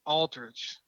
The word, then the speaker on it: Aldrich: It was interesting to see Tony go after Aldrich. I said it right this time. – You did.